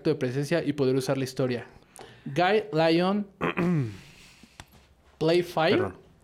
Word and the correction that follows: Playfair: Eh, o Playfair.